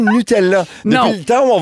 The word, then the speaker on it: Nutella: Oh, moi, j'aime ça m'adapter parce que si la vraie prononciation, c'est Nutella, j'aurais tendance maintenant à y penser tout le temps parce que tu me l'as appris.